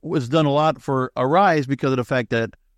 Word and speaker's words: Aeriz: And joining me right now to go talk about it is the marketing director at Arise. And that is spelled A-E-R-I-Z.